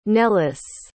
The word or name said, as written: nellis